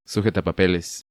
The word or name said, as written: Sujetapapeles